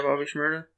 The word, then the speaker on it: Bobby Shmurda: Am I saying that right? – Yeah, Bobby Shmurda.